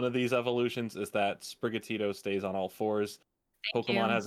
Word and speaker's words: Sprigatito: Sprigatito.